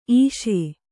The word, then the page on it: ಇಸಿ: Kannada